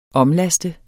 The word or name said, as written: omlaste